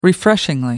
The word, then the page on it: refreshingly: British English